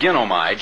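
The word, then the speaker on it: ginomai (γίνομαι): Until, now here's where the word ginomai comes in. The expression taken out of the way, if you look in the Greek Expositor's New Testament or the Diaglott, is the Greek word ginomai, G-I-N-O-M-A-I, as we would phonetically spell it in our English language, and it means become to be.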